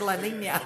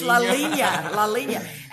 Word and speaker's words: La Linea: So y'all need to get down here to, and I say the name of it again, I keep saying it wrong. La Linea. I don't know why I want to say La Linea. It's not. It's La Linea.